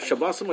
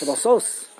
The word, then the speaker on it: Shabbosos: How do you say plural? Shabbosim or Shabbosis? – Shabbosos. – Shabbosos. – There's nothing to talk about.